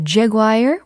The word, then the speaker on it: jaguar: 제규어가 jaguar. 이렇게 미국식으로 발음이 되고요. 영국식으로 어, 종종 jaguar 이렇게 발음이 될 때가 있습니다. jaguar 그리고 jaguar 이렇게 미국식 영국식으로 아 영국식이라면 jaguar의 er를 빼야 되겠죠.